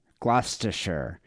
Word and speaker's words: Gloucestershire: How do you say that? – Gloucestershire.